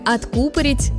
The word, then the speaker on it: откупорить: В слове «откупорить», «закупорить» ударение падает на второй слог, как, например, в медицинском термине «закупорка».